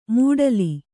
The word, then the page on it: ಮೂಡಲಿ: mūḍali; Kannada